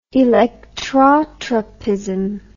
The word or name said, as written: electrotropism